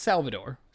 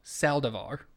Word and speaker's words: Saldivar: Not Salvador. Saldivar. – Saldivar. – Saldivar. – I mispronounced it.